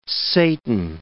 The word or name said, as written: Satan